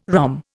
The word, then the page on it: rhomb: Indian